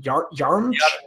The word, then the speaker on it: Yaremchuk: Yaremchuk.